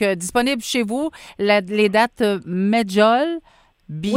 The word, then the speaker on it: Medjool: C'est dans les années 50 que la date Medjool, c'est comme ça qu'on la prononce? – Oui.